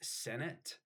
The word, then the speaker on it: Senet: So, um, the oldest board game known to man uh, was is is I believe it's pronounced Senet. It's it's called Senet mm.